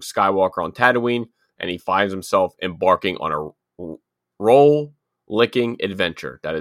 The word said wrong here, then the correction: rollicking: It says roll-licking. – Spell it. – R-O-L-L-I-C-K-I-N-G. Oh, rollicking? – Rollicking. – Rollicking, yeah.